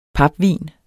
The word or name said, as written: papvin